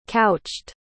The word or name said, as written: couched